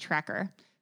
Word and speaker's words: Trakr: And I hope I'm saying it right because it's spelled T-R-A-K-R. But I'm pretty sure it's pronounced Tracker.